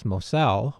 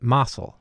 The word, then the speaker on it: Mossell: And I think our two guests, Heidi and Melissa, have told us that in Lockport, it's actually pronounced as Mossell, while the family pronounces it Mossell. So we've chosen to go by the family and call the family the Mossell family.